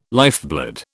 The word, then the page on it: lifeblood: British English